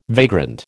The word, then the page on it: vagrant: American English